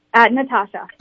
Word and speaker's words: Natasha: Is it Natasha or Natasha? Which do you prefer? – Natasha.